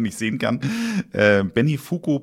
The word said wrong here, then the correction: Benifuki: Powder Ichibancha. Habe ich es richtig ausgesprochen oder war es ganz falsch? – Benifuki Pulver Ichibancha.